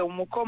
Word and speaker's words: Umuco: Sì, un progetto Umuco, si scrive Umuco ma è Umuco.